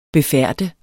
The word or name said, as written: befærdet